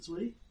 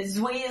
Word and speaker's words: Zwi: So, Zwee? Is it Zwee? – Well, pronunciation? – Yeah, Zwee is the pronunciation, yep.